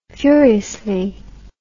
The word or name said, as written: furiously